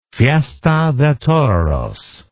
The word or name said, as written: fiesta de toros